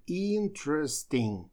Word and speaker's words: interesting: Но и слово это тоже интересное, потому что вы видите, написано interesting, но получается, что мы первую букву и не читаем, а сливаем t с r, получается interesting. То есть здесь идет потеря звука, он почему-то существует в написании, но в произношении его не говорят.